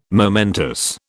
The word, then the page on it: momentous: British English